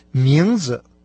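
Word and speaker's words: мензе (名字): Прежде всего, имя по-китайски будет «мензе». Сейчас начальный слог этого слова произносится со вторым тоном, последующий с легким.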